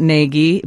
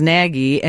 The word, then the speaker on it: Nagy: Someone says that he does pronounce it Nagy, but the media says Nagy, and he just lives with it.